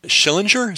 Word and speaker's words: Schillinger: I know that the roots of Berkeley was a guy named Joseph Schillinger.